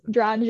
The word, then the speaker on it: Drange: No, that's perfect pronunciation. I was on a podcast before and they pronounced it with like a French accent. It was Drange. And Drange.